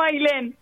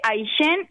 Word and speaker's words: Ailén: ¿Se escribe A-Y-L-E-N? – No, mi nombre es uno de los pocos que conozco que se escriben A-I-L-E-N. Se escribe Ailén y se dice Ailén.